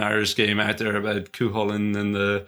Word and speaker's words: Cúchulainn: Josh can spell Cúchulainn.